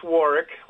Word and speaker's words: Warwick: It's Warwick, like a K.